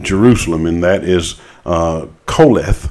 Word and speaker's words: Qoheleth: Uh, it's spelled, it, it transliterated in English as Q O H E L E T H, but it's pronounced in the best southern uh, Hebrew I can do, uh, Koleth.